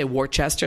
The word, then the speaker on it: Worcester: It's spelled W-O-R-C-E-S-T-E-R. Being a good German person, you know, you Worcester. Pronounce every letter. So I would say Worcester, and they're going, no, it's Worcester. – Oh, okay. – W-O-O-S-T-E-R. That's how it's said.